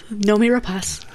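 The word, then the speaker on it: Noomi Rapace: This film does bring in a new character played by Noemi Rapace. – Yes. – I just looked up how to say her name yesterday, so... – Said it right. – Yes. Noemi Rapace.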